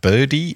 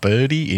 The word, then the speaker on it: Brdy: If you're from New Zealand, check out Birdie Internet. That's BRDY. That's right. It is spelt messed up. Birdie Internet.